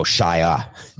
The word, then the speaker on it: Shia: It's called Honey Boy, not because of Honey and Boys, because his dad would say things that were so, it made Shia, is it Shia or Shia? Shy? I don't know how to pronounce his first name. I get it wrong. Shy. So, and, oh, Shia. Thank you. Coca said Shy and then it was quiet. So I thought it was Shy. Shia.